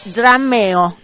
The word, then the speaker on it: Drumeo: Vamos a hablar del canal de YouTube que se llama, se los digo cómo se escribe y luego la pronunciación, Drumeo o Drameo, drameo, drumeo.